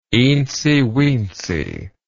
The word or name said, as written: eensy weensy